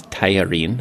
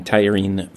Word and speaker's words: tajarin: that is spelled T-A-J-A-R-I-N. My best shot at the pronunciation is tagliarine. That's what people seem to say.